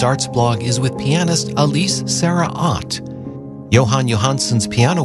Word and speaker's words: Alice: Coming up in just a moment, a pair of waltzes by Frédéric Chopin, performed by the pianist Alice Sarah Ott, who I have for years been mispronouncing. I was pronouncing it the, you know, American way, Alice Sarah Ott. She's from Germany, where it is Alice.